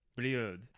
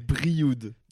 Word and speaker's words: Brioude: Brioude. – I think you're never gonna... – How do you say it? How do you say it properly? – Brioude. – Brioude. – This is the R.